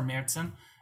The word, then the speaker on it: Märzen: And then we actually do a year-round Märzen, which you might know that more as an Oktoberfest, but we call it a Märzen, or Märzen is the correct German pronunciation.